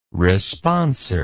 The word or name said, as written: responser